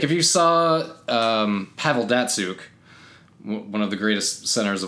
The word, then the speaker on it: Datsyuk: But if you saw his last name, you would have no idea how to say it. – Mm. – It's Datsyuk. So you think Datsyuk? – But it's Datsyuk.